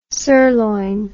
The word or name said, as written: surloin